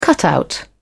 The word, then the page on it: cutout: British English